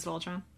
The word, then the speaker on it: Voltron: I said that right? It is Voltron.